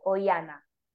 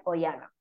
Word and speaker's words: Oihana: Y en Instagram soy Cuerpo Emocional by Oihana, que mi nombre se escribe O y latina Hana. Cuerpo Emocional by Oihana.